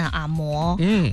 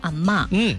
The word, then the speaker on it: 阿嬷: 所以来自各方的看法，我们先来说说，就是专家啊，中国当代语言学家林伦伦在一场的这个座谈会当中，专家说了，大家不要念阿嬷，要说阿嬷，说这个阿嬷，因为这个字单独在字典里查，它只有这一个嬷的读音嘛。